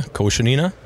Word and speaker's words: Koshenina: Yeah, Koshenina.